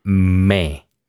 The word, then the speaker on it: me: The pronunciation is me. And the sound is the same sound that we are going to find in the word egg. Okay, so it's an open E, me.